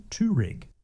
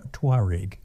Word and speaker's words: Touareg: but everyone at Volkswagen, and indeed virtually everyone everywhere, pronounces Touareg. So they lose one whole syllable in there. So it's the Volkswagen Touareg.